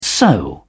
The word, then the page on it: so: British English